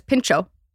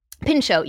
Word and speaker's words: pintxo: So you know the right way to say this. Pincho. – Pincho. P-I-N-T-X-O. Mm-hmm. That T and the X make that ch.